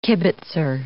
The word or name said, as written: kibitzer